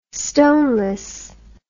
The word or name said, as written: stoneless